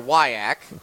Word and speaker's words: WIAC: I will not entertain the debate of whether you pronounce that WIAC or WIAC. How do you say it? – I go WIAC.